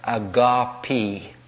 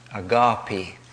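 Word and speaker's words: agape (ἀγάπη): The Greek scholars in the United Kingdom in our community tell us that the word is actually pronounced agape. Agape, not agape.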